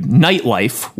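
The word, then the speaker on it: Knight Life: His original works include Nightlife, with a K, silent K, quote, a satirical fantasy in which King Arthur returns to contemporary New York and runs for mayor, end quote.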